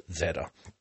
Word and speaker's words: zeta: Ireland is where I learned that the Z is pronounced Zeta. Is said to be Zeta instead of Z.